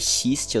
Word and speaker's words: Xiste: É, a música dele com o Rincon, mais uma vez citando aqui, é, o nome dela é Xiste, eu tô vendo aqui agora, eu é. falei errado. eu falei Piste, mano. – É, eu falei Mist.